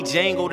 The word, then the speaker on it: Django: Call me Django, the D is silent till I am in it.